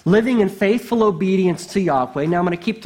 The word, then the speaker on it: Yahweh: We're talking about a covenant God, and God revealed himself as Yahweh. That's the way we most of the time say it.